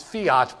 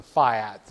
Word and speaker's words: fiat: It's actually pronounced fiat, but I've been told it's better to pronounce it fiat these days.